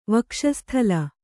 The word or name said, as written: ವಕ್ಷಸ್ಥಲ